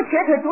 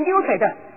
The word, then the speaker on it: 瘸腿: 什么瘸腿读成溜腿的。